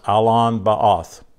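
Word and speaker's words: Allon Bacuth: And it's Alon, I'll spell it to you, A-L-L-O-N hyphen B-A-C-U-T-H. And it's pronounced Alon Ba'oth.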